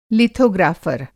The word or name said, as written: लीथोग्राफ़र